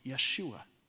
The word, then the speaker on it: Yeshua: Well, his name is Joshua, or they would pronounce it Yeshua, which we know is exactly the way Jesus would have pronounced his own name.